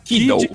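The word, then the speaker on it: Kiddle: Kindle, não sei como é que se pronuncia, é Kiddo, Kiddo, né? É. É K-I-D-D-L-E.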